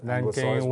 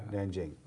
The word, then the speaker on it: Nanjing: Is that just a Nanking was under the older sort of pronunciation system. It was Nanking. – Oh, there's a new system. Okay, yeah. Nanjing.